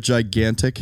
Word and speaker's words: gigantic: Do you ever notice that the word gigantic has two G's in it that are pronounced completely different? – That's true.